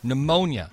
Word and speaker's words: pneumonia: Pneumonia. It's a silent P, I see.